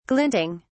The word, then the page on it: glinting: British English